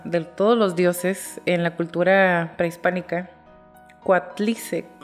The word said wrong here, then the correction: Coatlicue: siempre lo digo mal, Coatlicue.